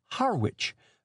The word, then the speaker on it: Harwich: Harwich is the way it is mouthed in the seaport town of Essex County, England. The Harwich of Cape Cod is pronounced Harwich.